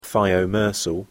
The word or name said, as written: thiomersal